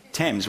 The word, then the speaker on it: Thames: Is it Thames? How do you pronounce that river? Thames. Why is it spelled with an A?